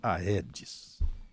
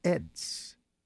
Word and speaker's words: Aedes: Não é Aedes, é Aedes. O AE latino tem som de E.